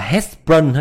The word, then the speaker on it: Hertzsprung: Đó, đây là biểu đồ đọc là Hertzsprung ha các bạn.